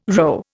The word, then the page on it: rho: Indian